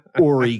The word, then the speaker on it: Horry: I'm assuming this isn't the end of their reign of terror all over Horry County, South Carolina with the silent H.